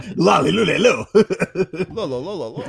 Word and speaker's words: Lololo: I imagine it's pronounced like DDD, so it's Lololo.